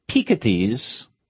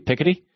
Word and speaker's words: Piketty: The other thing I did on this trip is I read a large chunks of Thomas Piketty's. Yeah, it's the French pronunciation, not the. It looks like it should be Piketty, but it's Piketty or Piketty anyway. So that's how it's pronounced.